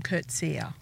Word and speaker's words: Coetzee: Coetzee.